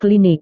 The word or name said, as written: klinik